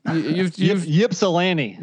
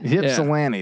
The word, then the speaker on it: Ypsilanti: Well, how do you say that? – Ypsilanti. – Ypsilanti. Yeah. That's what it is.